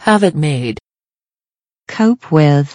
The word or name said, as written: have it made